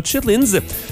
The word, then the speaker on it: chitterlings: C'est comme ça qu'on prononce. Donc, chitterlings, ça s'écrit chitterlings, mais on prononce ça chitlins.